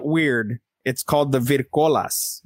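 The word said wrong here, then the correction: Vrykolakas: Virkolakas. There you go.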